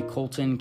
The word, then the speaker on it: Colton: My Twitter, Instagram, and Facebook username is simply Colton Candy, and it's not spelled candy, it's spelled C-A-N-D-E-E, and then C-O-L-T-O-N, because that's my first name.